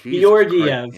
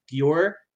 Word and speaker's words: Georgiev: And if Georgiev, you say his name wrong. Oh, Georgiev.